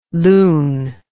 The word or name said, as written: loun